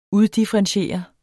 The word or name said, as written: uddifferentiere